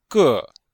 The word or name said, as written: ge4